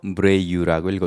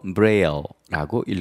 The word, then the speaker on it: Braille: Braille. Braille. – 점자. 이것은 프랑스의 루이 브레유라는 사람의 이름을 따서 만들었습니다. 그래서 프랑스식으로 브레유라고 읽어도 되고 영어식으로 브레일라고 읽어도 됩니다.